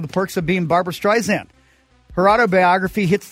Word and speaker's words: Streisand: My name isn't spelled with a Z, she argued. it's Streisand like sand on the beach.